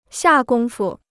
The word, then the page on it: 下功夫: xià gōng fu